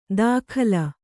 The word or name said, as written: ದಾಖಲ